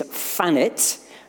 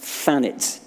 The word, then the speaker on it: Thanet: The correct pronunciation is Thanet, not Thanet, just in case.